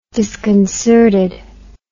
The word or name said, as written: disconcertedness